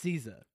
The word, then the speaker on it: SZA: It's pronounced SZA.